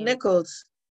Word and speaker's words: Nichols: It's Nichols.